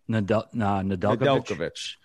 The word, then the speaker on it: Nedeljkovic: Nedeljkovic.